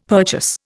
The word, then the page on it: purchase: Indian